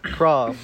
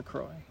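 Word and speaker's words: La Croix: The white people say it wrong. The Croix. That's wrong. Incorrect. – That's the French got it right.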